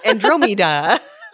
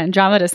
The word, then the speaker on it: Andromeda: But I didn't know how to pronounce. Andromeda. – Andromeda. – Yeah, Andromeda sounds way better.